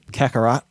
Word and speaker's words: Kakarot: Kakarot.